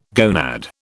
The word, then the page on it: gonad: British English